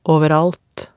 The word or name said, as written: overalt